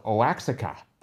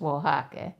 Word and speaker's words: Oaxaca: I was going for Oaxaca. – Uh-huh. No, it's Oaxaca.